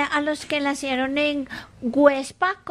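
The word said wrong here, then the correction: Güepsa: Perdón, Huepsa. Websa. Con diéresis.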